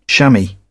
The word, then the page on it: shammy: British English